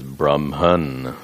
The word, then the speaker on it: Brahman: Notice I'm using the short A. Long A is Brahmaji, the creator. The short A distinguishes Brahman, the Sarva Adhishtana.